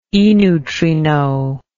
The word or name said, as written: eneutrino